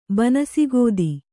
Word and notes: ಬನಸಿಗೋದಿ: banasi gōdi

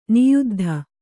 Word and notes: ನಿಯುದ್ಧ: niyuddha

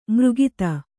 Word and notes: ಮಾರಕತ: mārakata

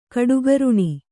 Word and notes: ಕಡುಗರುಣ: kaḍugaruṇa

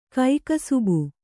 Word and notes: ಕೈಕಸುಬು: kannada

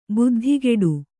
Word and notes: ಬುದ್ಧಿಗೇಡಿ: buddhigēḍi

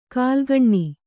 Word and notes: ಕಾಲ್ಗಣ್ಣಿ: kālgaṇṇi

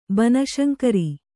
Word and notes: ಬನಶಂಕರಿ: kannada

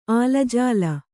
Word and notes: ಆಲಾಜೋಲಿ: ālājōli

